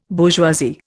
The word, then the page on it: bourgeoisie: Indian